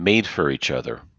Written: made for each other

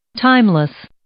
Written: timeless

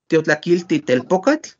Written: tiotlakilti telpokatl

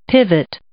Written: pivot

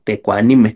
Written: tekuanimej

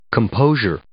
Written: composure

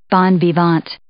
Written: bon vivant

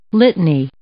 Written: litany

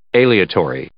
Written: aleatory

